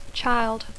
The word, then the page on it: child: English